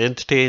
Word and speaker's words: NTT: Anyway, yeah, so the game itself, it's been put together using the Entity Engine, which is spelt NTT.